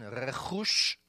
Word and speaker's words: rechush: And here's a nice Hebrew word with guttural pronunciation, rechush. Can you say rechush?